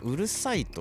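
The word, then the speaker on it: 五月蝿い: ハエっていう漢字なんですけどこれ「うるさい」と読むん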